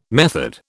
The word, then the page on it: method: British English